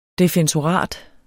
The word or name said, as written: defensorat